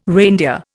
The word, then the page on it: reindeer: Indian